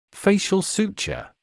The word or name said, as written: facial suture